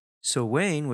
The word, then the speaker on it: Samhain: Samhain, which is spelled S-A-M-H-A-I-N, that means the end of summer.